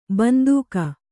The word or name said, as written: ಬಂದೂಕು